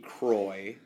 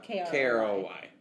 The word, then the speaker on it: Kroy: Croy, but it's not spelled right. – No, it's spelled K-R-O-Y. Yeah, K-Roy.